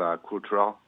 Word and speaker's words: Kurtaral: As it's spelled, Kurtaral.